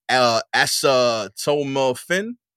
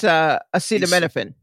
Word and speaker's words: acetaminophen: As reported by CNN, a new challenge on social media encourages participants to cook chicken in a mixture of acetaminophen, acetaminophen? – No, that's acetaminophen.